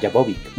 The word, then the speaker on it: Jovovich: Mila, ¿cómo se dice su apellido? Jovovich. – Jovovich. – 43. – Ah, Jovovich. Muy bien.